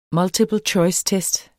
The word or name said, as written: multiple-choice-test